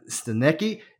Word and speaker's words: Stanecki: All right, here we are, episode 5, with none other than Mr. Adam Stanecki.